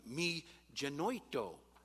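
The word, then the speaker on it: mi genoito (μὴ γένοιτο): Did a little research in the Greek, and the phrase is pronounced mi genoito,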